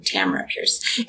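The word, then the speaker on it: Tamora Pierce: I recently started reading some of, this is old school as well, Tamara Pierce, or Tamara Pierce, I guess she pronounces her name, Tamara Pierce.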